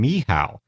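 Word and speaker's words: Michał: This was sent to me by a guy living in London who pronounces his name Mihao, his name looks nothing like Mihao, but that's how he says it's pronounced. So how does it look? – It looks like Mikkel or Michael, but it's pronounced Mihao.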